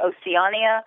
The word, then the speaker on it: Oceania: So Europeans on board the ship pronounce it Oceania. In headquarters, Miami, and the travel agents and our sales reps around the country, we say either Oceana or Oceana.